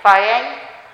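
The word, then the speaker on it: Thyatira: She was from the city of Phaiatia. No, it's pronounced, Phaiatira.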